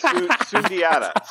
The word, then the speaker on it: Sundiata: How are you pronouncing it? Say his name. – Sungiata. – It's are you saying it right or am I saying it right? – I don't know. – He's been saying it the whole time or did he just copy my pronunciation? – I don't know. I thought it's Sundiata. Sundiata game.